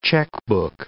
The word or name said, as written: checkbook